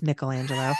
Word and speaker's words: Michelangelo: They're like Michelangelo and, which is by the way, how you pronounce it, folks, it's Michelangelo.